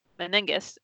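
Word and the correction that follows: Megennis: and it's McGinnis.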